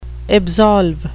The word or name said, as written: absolve